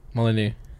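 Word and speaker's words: Molineux: Say it properly. – Molyneux. – Okay. Just checking.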